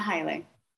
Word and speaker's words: Haile: It's a common mispronunciation. It's Haile, actually. Erina Haile.